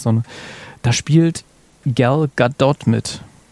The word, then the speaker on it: Gadot: Da haben sie die gefragt, wie ihr Name eigentlich ausgesprochen wird. Und die wird Gadot ausgesprochen, weil die aus Israel kommt. Für alle anderen Podcasts auch. Für euch. Also, Gal Gadot aus Gal Israel.